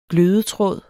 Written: glødetråd

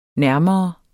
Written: nærmere

